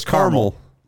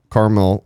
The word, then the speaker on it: Carmel: No, it's Carmel. – Carmel. – Carmel. – It doesn't have a second A, though, right? It's just C-A-R. – It's just Carmel, not caramel.